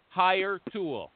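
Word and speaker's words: Hiretual: Mom's I want to talk to you today about Hire Tool. I'm going to spell it for you because it's got an odd spelling. H-I-R-E. Go get one. H-I-R-E-T-U-A-L. H-I-R-E-T-U-A-L.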